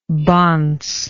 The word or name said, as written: bonce